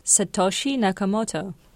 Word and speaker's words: Satoshi Nakamoto: Satoshi Nakamoto.